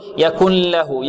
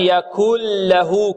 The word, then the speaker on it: يكن له: ندغم النون في اللا وننطق بلام خالصة. ولم يكن له، بعض الطلاب يقول يكن له يكن له يكن له، هذا خطأ. ولم يكن له كفؤا أحد، هذه الكلمة كلها ماذا؟